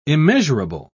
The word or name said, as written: immeasurable